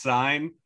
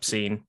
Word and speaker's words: Cine: Lewis Cine. – Nope, Cine. – Cine, yep. All right.